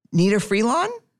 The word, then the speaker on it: Nnenna Freelon: I Say a Little Prayer by Nnenna Freelon.